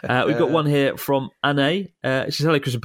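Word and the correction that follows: Anaïs: It's spelled A-N-A-I-S. Anne, right? – Come on. But she sent the katakana version. – Oh, damn. – There's an S in there at the end. – Okay, yeah. I've said it wrong. So Anne continues. It's okay, said my name wrong. I've never met an English speaker who can pronounce it correctly. I'll help you this time using katakana. It is Anaise.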